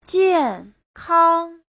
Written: jian4kang1

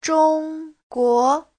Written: zhong1guo2

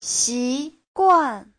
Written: xi2guan4

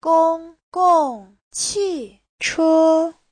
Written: gong1gong4qi4che1

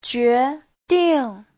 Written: jue2ding4

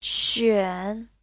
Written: xuan3

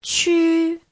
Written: qu1

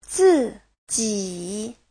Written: zi4ji3

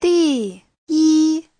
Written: di4yi1

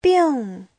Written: bing4

